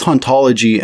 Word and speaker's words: hauntology: In the original French, the age in haunt is silent, and therefore both hauntology and ontology are in fact pronounced the same.